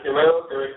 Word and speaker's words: Guerrero: Yeah, that's how you pronounce it, Guerrero.